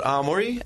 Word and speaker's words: Aomori: The prefix is called Aomori, and the place I live is called Hirosaki.